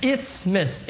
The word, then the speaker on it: isthmus: It's an isthmus. It's spelled I-S-T-H-M-U-S.